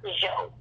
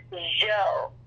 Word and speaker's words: Zso: So it's I am Merna Zhou, one word. And that's Zhou, like like the Jean Garage Massage Mirage. A lot of people don't know how to pronounce my last name. That's Merna Joe.